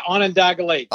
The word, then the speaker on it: Onondaga Lake: So there's a story in the book where you're talking about, and I'm probably going to butcher the name, so forgive me, but I think it's Onondaga Lake. – Yeah, Onondaga Lake. Yeah. – Oh, nailed it.